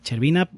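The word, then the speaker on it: Shcherbina: Además, gira en torno de la figura de Boris eh, Chervina, Cher, lo he dicho bien, ¿no? Sí, Chervina,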